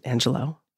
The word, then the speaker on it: Angelou: When I listened to Dr. Maya Angelou, I found out it's called, she pronounced it Angelou in this, thank you.